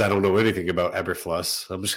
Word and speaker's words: Eberflus: We also had the Bears make their signing with Matt Eberflus. I don't know if that's pronounced right. – I think it's Eberflus. – Eberflus. Eberflus. That sounds much better.